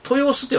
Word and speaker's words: 豊洲: でもちらほらなんですね、うん、これは豊洲って読むんかな。